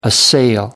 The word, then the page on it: assail: American English